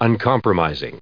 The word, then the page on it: uncompromising: un*com*pro*mis*ing; American English